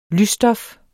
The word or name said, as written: lysstof